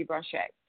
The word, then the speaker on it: Branché: That's the correct spell, the correct way to pronounce my name would be Branché, but because most people can't say it, and if you've heard the way my name has been butchered, I found it easier to say Stacye Branche, and then people just work with that.